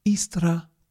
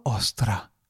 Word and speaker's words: Eostre: But nevertheless, the producer would like to step in and tell you now, the audience of Roger and Louise Celebrate, the real pronunciation. There are two options available. It's either Istra or Ostra.